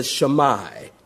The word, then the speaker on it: Shema: Jesus is quoting from what the Jews call the Shammai. Taking notes, S-H-E-M-A, the Shammai. Some pronounce it Shema.